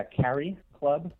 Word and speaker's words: CariClub: Yeah, Carry Club.